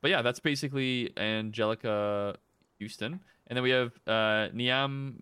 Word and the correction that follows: Niamh: Moyles. – Neve. – Sorry? – Ooh, okay, that's the one how you pronounce that. – Neve Moyle, sorry.